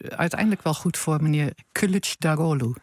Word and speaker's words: Kılıçdaroğlu: Zo zeg je dat. – Kılıçdaroğlu. De I is een U, hè? – Ja, en de G met een dakje erop, die spreek je niet uit. Die verlengt de klinken daarvoor. Dus niet Kılıçdaroğlu of zoiets.